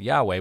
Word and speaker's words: Yahweh: So God's revelation of his name is the name that we pronounce as Yahweh.